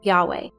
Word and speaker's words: Yahweh: The name of God, I Am, was written with these four consonants, YHVH, that the Jews pronounced like this, Yahweh. The pronunciation Yahweh is undoubtedly the most correct pronunciation of the original Hebrew to indicate God as I Am Who I Am.